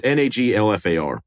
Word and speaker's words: Naglfar: Is there an L in there? Like Naglfar or Nagwafar? – Yeah, that's why I don't know if I'm pronouncing it. It's Naglfar.